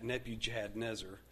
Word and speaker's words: Nebuchadnezzar: Now, by the way, if you're trying to spell Nebuchadnezzar, um, you can, of course, look in your Bible, but I remember it Nebu Chad Nezer. Nebu Chad Nezer. All right, so you don't want to say that, Nebuchadnezzar.